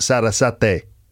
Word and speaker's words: Sarasate: Suddenly Holmes gets up and suggests that they go into the city to see a music concert, Sarah Sate. I don't know if I'm pronouncing it properly. Yeah, I just checked and I got that wrong. It should be pronounced Sarasate or Sarasate.